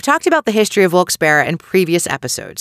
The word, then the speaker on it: Wilkes-Barre: The first thing I need to tell you about this town is you may hear the name pronounced differently. Some people, actually most locals I know, pronounce it Wilkes-Barre. Some people say Wilkes-Barre, and that's a no-no. Forgive me to those of you whom pronounce it that way, but you need to change that up.